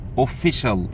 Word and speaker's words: official: official CIA تنطق شين، خلاص كده official، وما تقولش official غلط، official خلاص كده، official خلاص كده، official language.